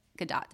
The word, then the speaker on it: Gadot: And I would love to see a Nubia and Wonder Woman, like, movie with Gal Gadot. I think that would be, Gadot. – Did I say it wrong? – Mm-hmm. – Okay, thank you for correcting me.